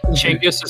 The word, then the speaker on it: Chinggis: Chinggis is how it's pronounced in Mongolian.